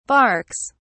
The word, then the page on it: barks: British English